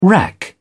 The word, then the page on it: rack: British English